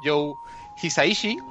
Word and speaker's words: Joe Hisaishi: Bueno, pues podemos empezar contando un poquito sobre el compositor, el hombre de nombre muy impronunciable que se llama Joe Hisaishi, uy, me ha salido bien.